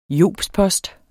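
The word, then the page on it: JOBSPOSTER: Danish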